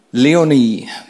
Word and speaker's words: Leoni: Ich habe mir als Leoni, ich, ich, ich sage es immer Leoni, mein Bruder hat mich heute korrigiert, äh, wird nicht Leoni ausgesprochen, sondern Leoni. Ein bisschen anders betont, ne?